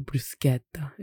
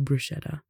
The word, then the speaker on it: bruschetta: So like bruschetta is what some people say, bruschetta. The CHE would be a K sound instead, so that would be a bruschetta instead of bruschetta.